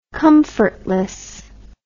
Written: comfortlessness

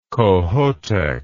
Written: Kohoutek

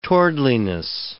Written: towardliness

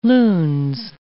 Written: lunes

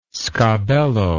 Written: sgabello

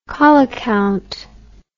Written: coli count

